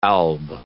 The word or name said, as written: alb